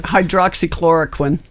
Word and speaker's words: Hydroxychloroquine: Hydroxychloroquine. – Hydroxychloroquine.